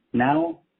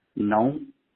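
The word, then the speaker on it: não: So you said that the pronunciation of N, A with a tilde, and O is now, but it's not. It's known. And S-A-O is son.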